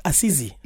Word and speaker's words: Assisi: Uh, it's Assisi. Yeah. Not Assisi. Assisi. – Oh, that's how they pronounce it.